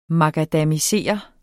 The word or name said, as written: makadamisere